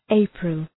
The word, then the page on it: April: ‘eıprəl